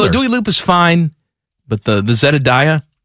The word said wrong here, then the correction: Zendaya: I think it's Zendaya. I think I've been saying it wrong.